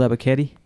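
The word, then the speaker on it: Ebiketie: Kevin Ebikite. Is that how you say it? – All right. – Arnold Ebikite.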